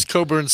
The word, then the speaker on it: Cockburn: Bruce Coburn spells it C-O-C-K-B-U-R-N, but don't pronounce that like the way it's written.